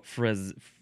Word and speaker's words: frieze: Is that an A or an A? Oh, yes. It's a long A. So frize, I guess? Frize. It is spelled F-R-I-E-Z-E. So it's frieze or frize.